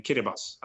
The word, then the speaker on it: Kiribati: And you see Kiribati, for instance, it's actually spelled K-I-R-I-B-A-T-I, but it's pronounced Kiribati. So T-I in Kiribati is pronounced as S.